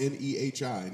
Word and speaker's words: Nehi: N E H I.